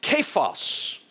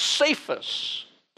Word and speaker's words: Cephas: This man's name in the Aramaic is pronounced Kephas. In the Greek, it is pronounced Cephas, and we know him as Peter.